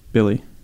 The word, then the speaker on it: Billie: It's spelled B-I-L-L-I-E. – And pronounced Bill? – Billy. B-I-L-L-I-E.